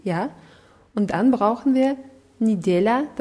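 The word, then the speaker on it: Nidela (неделя): Ja, Nidela wird mit zwei Je geschrieben, Nidela. Das erste Je wird aber wie I ausgesprochen, weil es unbetont ist.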